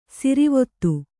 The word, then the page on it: ಸಿರಿವೊತ್ತು: sirivottu